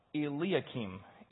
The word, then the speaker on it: Eliakim: Eliakim.